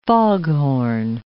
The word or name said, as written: foghorn